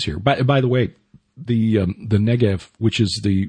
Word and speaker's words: Negev: But so, it may be pronounced with a V, because spelled with a B are actually pronounced as a V. So. – Well, that's my point. That one that one most certainly is, because today we know it as the Negev.